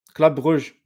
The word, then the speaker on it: Club Brugge: Club Brugge, yeah.